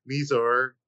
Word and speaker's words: Mizar: So we see Mizar. I said it right. – Mm-hmm. Hey, you got it right that time.